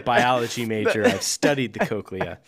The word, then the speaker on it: cochlea: Well, it's the cochlea, which is a part of the ear, but it's spelled C-O-C-H-L-E-A.